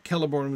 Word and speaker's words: Celeborn: You may remember way back, Celeborn was originally written with a K, and then he changed it to a C, though still a hard C sound.